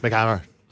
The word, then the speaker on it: MC Hammer: When Olivia started on Attack of the Show, she said McHammer instead of MC Hammer. – I know. – McHammer. – You know that Irish songster? – Yes. McHammer.